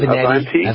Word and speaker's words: Avenatti: Avenatti.